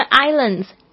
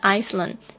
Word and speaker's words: Islands: 这个 Islands 这个要特别注意一下，有很多的同学呢会把它念错，会念成 Iceland, 其实不是哦，中间的这个 S 是不发音的， The S is silent, 只是不发音的意思。